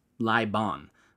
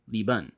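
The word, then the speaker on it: Lý Bôn: Incidentally, if you happen to look up this guy's name in modern Vietnamese, it looks like it ought to be pronounced Lai Bon, but it is Li Bun.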